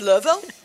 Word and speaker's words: Louisville: So I'm from Louisville, Kentucky. – Louisville. See how she said Louisville? That's how the locals say it. – Yeah, no syllables, just Louisville.